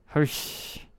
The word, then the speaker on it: Hirsch: Hirsch.